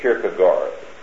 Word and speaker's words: Kierkegaard: K-I-E-R-G-E. K-I-E-R-G-E. K-I-E-R-K-E, excuse me, G-A-A-R-D. Kierkegaard, it sounds, but Kierkegaard pronounced.